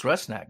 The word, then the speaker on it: Rusnak: It's Rusnak.